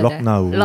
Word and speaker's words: Lucknow: 물론 이제 현지어는 조금 다를 거예요 발음이. 네. 근데 영어로 표기할 때 LUCKNOW 이렇게 쓰잖아요. 네네네. 럭나우.